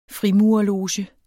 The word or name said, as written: frimurerloge